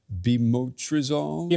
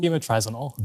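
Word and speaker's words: Bemotrizinol: Is it Bimotrizol? – Bimotrizinol. – Bimotrizinol. Yes, exactly.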